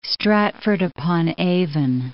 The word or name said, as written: Stratford-upon-Avon